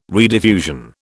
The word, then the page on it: rediffusion: British English